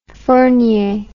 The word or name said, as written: Fournier